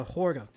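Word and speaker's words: Hårga: Is it Helga? – Oh, the Harga? The village? – Yeah. – The Horga. – Horga, yeah. – Horga? – Horga. That A with the ring over it, that actually makes it like an O sound.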